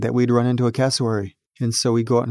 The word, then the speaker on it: cassowary: cassowary. Did I say that right? – That's right. The cassowary.